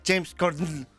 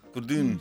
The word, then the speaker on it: James Corden: Este chico, James Corden, anda a pronunciarlo en inglés, James Corden. – Corden. – Se sacan como todo lo que tiene el apellido y te quedas con la N y algo más.